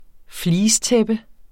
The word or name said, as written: fleecetæppe